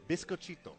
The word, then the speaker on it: biscochito: Biscochito.